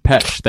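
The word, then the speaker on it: Pêche: Peche? – James Pesh.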